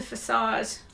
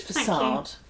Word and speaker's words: facade: There's medieval bits of carving, stonework worked into the facade. – Yeah. – Did I say that right this time? – You did. Facade. – Thank you. Facade. I was really conscious about saying facade, not facade.